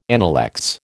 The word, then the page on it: analects: American English